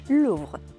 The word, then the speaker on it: Louvre: Louvre, pues muy bien dicho. – ¿Lo dejo en F o le meto un poco de grrr? – No, bueno, pues una Louvre.